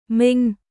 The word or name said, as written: Minh